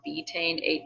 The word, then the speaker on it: betaine: But she also suggests a supplement called betaine or betaine HCI. It's spelled BETAINE and the HC1, sorry.